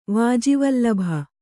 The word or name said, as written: ವಾಜಿವಲ್ಲಭ